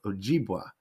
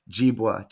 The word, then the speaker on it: Ojibwa: There's one in this book, both of these books here, Chippewa Customs, which the Chippewa is the other pronunciation of Ojibwa, Jibwa, Chippewa.